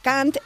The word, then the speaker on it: Cant: Y se pronuncia igualico, idéntico que la palabra cant, que es, significa en inglés coño. Se escribe de distinta manera, pero suena exactamente suena igual.